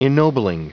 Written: ennobling

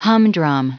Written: humdrum

